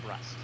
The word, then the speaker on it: Brust: That is Stephen Brust. – Oh, okay, so it's the same guy. Okay. So I've been saying his name wrong. I always thought it was Bruce.